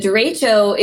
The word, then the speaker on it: derecho: You are right, derecho. – Derecho. Yeah. Okay. So what in the world is that, and are there more of those storms going to be coming? – Yes. So first question, a derecho,